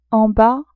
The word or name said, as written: en bas